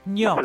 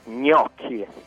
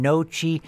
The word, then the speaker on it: gnocchi: I was saying nochi. I sounded like a real American, didn't I? Nochi gang. And it's gnocchi. Is that right? Gnocchi. – It's gnocchi. Yeah, that's it.